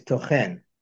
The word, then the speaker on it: tochen: And if you can see the nigun on here, tochen is the stress on the last syllable. It's tochen, which is a verb.